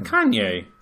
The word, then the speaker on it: Kanye: Kine West. All right, all right. Uh, you got caught that you were like part of pop culture, so now you have to butcher it. No, what's his name? Kanye.